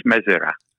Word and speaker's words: Mezera: Then, uh, but it's uh, it's pronounced Mazira actually. Yeah, but doesn't matter.